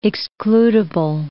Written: excludible

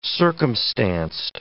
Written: circumstanced

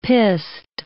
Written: pissed

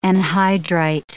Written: anhydrite

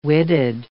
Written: witted

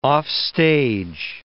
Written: offstage

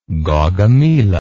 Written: Gaugamela

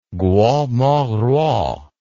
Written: Guo Moruo

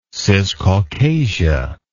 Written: ciscaucasia

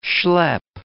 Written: schlepp

